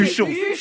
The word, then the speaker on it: Huchon: D'ailleurs, je l'ai mal dit, c'est Houchon. – C'est Huchon.